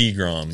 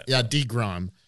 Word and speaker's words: deGrom: DeGrom. – Yeah, DeGrom.